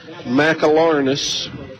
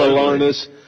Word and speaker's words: McAlarnis: Brings up first baseman Kyra McAlarnis. – Four syllables. – Yes, sir. – Four syllables. – And listen, did you see me counting on my fingers? – Absolutely. – McAlarnis.